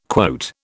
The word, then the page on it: quote: British English